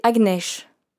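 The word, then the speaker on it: Agnes: Mon prénom c'est Agnèche. Ça s'écrit comme Agnès, son équivalent français, mais sans accent.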